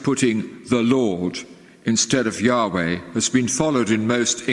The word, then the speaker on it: Yahweh: Shorter forms of this are YHWH, pronounced Yahweh or Jehovah.